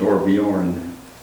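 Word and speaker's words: Torbjørn: Outlaw, Torborn, Johannes, Gunnild, Halvor, Cal, and Svenning, I guess. – That'd be Torbjorn. – Torbjorn, don't pronounce the B. – Is it J there? – Yeah.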